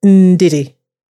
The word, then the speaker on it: Ndidi: So it's Ndidi, it's Ndidi. But if you talk to my mum, it's Ndidi. That's how you pronounce it.